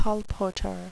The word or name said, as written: colporteur